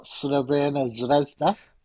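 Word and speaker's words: Crvena zvezda: or Slovena Zvezda. – One for the hipsters there. – Proper pronunciation.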